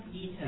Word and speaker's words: Ita: Ita. – Ita. – How do you spell it? – I-T-A.